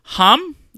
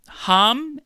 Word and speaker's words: Ham: Hum, I believe. – Hum. – Written out, it would look like H-A-M, but I ham. Believe it's hum, but it's not ham.